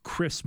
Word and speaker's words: Kris: Kris Murray, it's spelled K R I S, but Keegan Murray named Kris Murray after Chris Street and took inspiration from his former teammate.